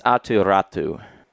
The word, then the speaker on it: Atu Ratu: This was, my Islanders took Atu Ratti, who was in conversation. – Apparently, it's Atu Ratu. – With a Y at the end? – Correct. That the Ratti is just pronounced Ratu.